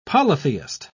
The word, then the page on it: polytheist: British English